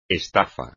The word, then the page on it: estafa: espagnol